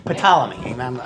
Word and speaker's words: Ptolemy: That's how I pronounce it, okay? Ptolemy.